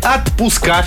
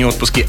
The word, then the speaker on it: отпуска: «Отпуска». Все-таки не «отпуски», «отпуска».